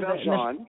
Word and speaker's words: Valjean: Valjean.